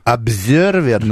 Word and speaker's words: Observer: Кстати, как «З» читается тут. В этом слове. – Обзервер. – Да. – Ну, просто на русский лад перевел.